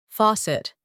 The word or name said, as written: faucet